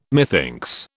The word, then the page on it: methinks: British English